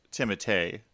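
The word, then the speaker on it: Timothée: Next, we get to our hero, Paul Atreides, played here by Timothy Chalamet. – Now, he sings. I'm not even going to address the problems you had with pronouncing his last name, but I believe it is Timotei, not Timothy.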